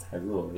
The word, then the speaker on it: Hyrule: Where's Hyrule? Hylane? Hyrule. – It's Hyrule, yeah.